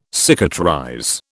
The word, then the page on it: cicatrize: British English